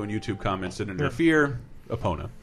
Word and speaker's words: Epona: But I know this should be Epona, because that's how they pronounce it in the Nintendo Epona. Of America videos.